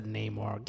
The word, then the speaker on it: Namor: It's 100% Namor. Get out. – Yeah, it's 100% Namor, dude. That's Namor.